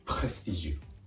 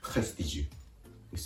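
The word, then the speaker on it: prestige: 続けて発音すると、プレスティジュ、プレスティジュです。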